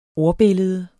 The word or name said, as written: ordbillede